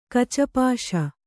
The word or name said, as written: ಕಚಪಾಶ